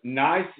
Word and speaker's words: Nice: If you're curious like I was, Nice is pronounced just like a nice glass of wine, but is actually a what for the first name of your husband's sibling? – It's an acronym for Ian and his sisters.